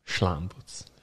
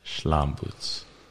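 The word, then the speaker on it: Slambuc: Hungarian pronunciation, so Schlambutz. – Schlambutz.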